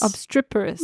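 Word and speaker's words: obstreperous: I can't pronounce it. – Yeah, no. – Obstriperous? Obstriperous? – Obstriperous? – Obstriperous. – Maybe? – Yes, I think that's probably right.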